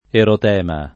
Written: erotema

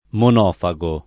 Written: monofago

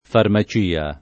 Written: farmacia

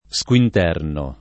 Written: squinternare

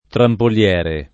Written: trampoliere

